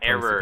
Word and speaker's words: Eero: The Eero, not really sure how it's pronounced, wireless routers that are supposed error.